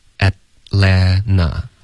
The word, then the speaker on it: Atlanta: 嗯，在美式的发音中，两个 T 都肯定省略。 – 那会读成什么呢？ – Atlanta。